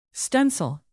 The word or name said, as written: stencil